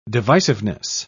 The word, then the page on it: divisiveness: British English